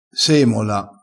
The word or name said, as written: semola